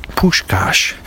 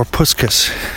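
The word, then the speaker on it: Puskás: Yes, there's a very successful and well-respected orthopedic surgeon in Thunder Bay who is Hungarian, and his name is Puskas, or Puskas, as my mostly non-Hungarian friends pronounce his name.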